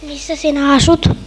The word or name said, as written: missa